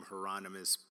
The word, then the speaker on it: Hieronymus: Right, because he explains, like, hey, I'm Hieronymus, rhymes with anonymous, here's the artist.